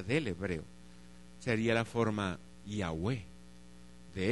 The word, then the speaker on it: Yahweh: Después lo pasamos como se pronunciaba esta forma, y sabemos que se pronuncia como Yahweh.